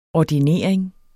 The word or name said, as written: ordinering